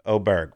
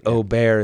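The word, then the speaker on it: Åberg: The Ryder Cup, there was a whole damn press conference about pronounce it Ober. It's pronounced Ober. We got strict instructions on that.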